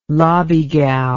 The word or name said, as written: lobbygow